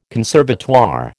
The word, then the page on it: conservatoire: American English